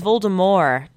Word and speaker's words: Voldemort: Apparently it's Voldemort. Did everyone see that on Twitter? That J.K. Rowling was like, the T is silent.